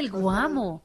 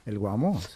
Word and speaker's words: El Guamo: Entonces, que Bésame, el Guamo. Se dice así. – Sí, claro, el Guamo.